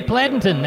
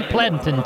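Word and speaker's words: Pleasanton: Let's head to Pladenton. That's the way the locals say it in Atascosa County, Pladenton, Texas, to talk to Jim Wheeler.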